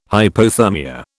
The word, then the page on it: hypothermia: British English